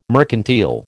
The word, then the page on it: mercantile: American English